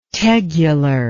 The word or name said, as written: tegular